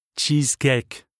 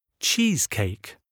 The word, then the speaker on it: cheesecake: Exemple. Cheesecake. Prononciation à la française. – Cheesecake. – Prononciation correcte. – Cheesecake.